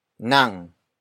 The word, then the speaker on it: nang: The next word is nang. Nang. Nang. Nang is spelled as ng.